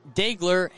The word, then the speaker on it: Dheygler: Daigler is D-H-E-Y-G-L-E-R, pronounced Daigler Jimenez.